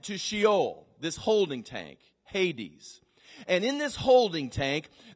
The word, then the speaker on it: Hades: But it was called Hades, H-A-D-E-S. It's literally, that's how it's pronounced in the Greek, Hades.